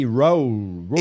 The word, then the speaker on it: Raoult: Didier Roult. R-A-O-U-L-T. Roult. – Didier Roult. – How do you pronounce that in French? You took French. – Didier Roult.